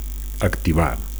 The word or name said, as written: activar